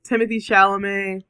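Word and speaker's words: Timothée Chalamet: Timothy Chalamet. – I think you got it. – Well, I know he doesn't pronounce it Timotei. Like he or he pronounces it like French.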